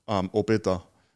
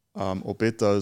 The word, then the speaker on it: Obed: So my grandfather in the Cook Islands, his name was Obeda, but Obeda is in the Bible. So, but in the Bible is pronounced Obed, but in the Cook Island we pronounce it with the A on the end.